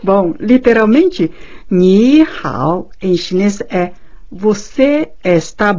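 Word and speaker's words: Ni hao (你好): Ni hao, ni, N-I, e hao, H-A-O, ni hao.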